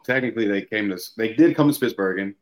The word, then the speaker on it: Spitsbergen: And I know didn't you say a couple guys from Germany came all the way up to Spitsbergen? Am I saying that right? To record with you? – Yeah. So, it is Spitsbergen,